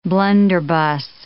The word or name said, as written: blunderbuss